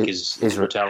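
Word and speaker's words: Israetel: I was listening to Mike Isra, I can't say his name. Mike Isratel. Isratel, yeah.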